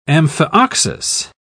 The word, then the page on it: amphioxus: British English